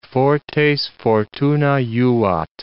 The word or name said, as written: fortes fortuna juvat